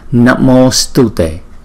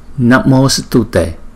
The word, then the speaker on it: Namo Stute: Chữ sờ này đọc nhẹ thôi, đừng có đọc là Stute. Namo Stute, Namo Stute. Rất là nhẹ chữ sờ.